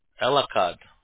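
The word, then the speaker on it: Elakkad: It's E-L-A-K-K-A-D. – A-D. Okay. And then how do you pronounce that, Nasar? – Nasser? – No, your last name. I've never seen that. – Oh, Elakad. – Elakad. Okay, I've seen your first name before, Nasser. – Nasser, A-S-S-E-R.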